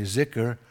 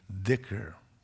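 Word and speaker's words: dhikr (ذكر): Just like we say zikr, well, if you were in Saudi Arabia some Arabic countries, they'd look at you like, excuse me, dhikr.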